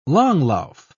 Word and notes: langlauf: British English

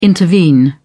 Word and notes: intervene: British English

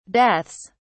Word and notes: deaths: British English

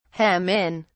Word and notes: hem in: British English

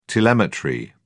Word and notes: telemetry: British English

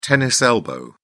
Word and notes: tennis elbow: British English

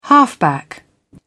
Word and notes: halfback: British English

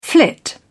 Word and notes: flit: British English

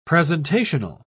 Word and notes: presentational: British English

